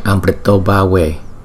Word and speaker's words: Amritobhave: Thì chữ A và chữ U liên tiếp với nhau thì chúng ta chuyển nó thành chữ O, cho nên chúng ta đọc là Amritobhave.